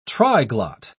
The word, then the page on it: triglot: British English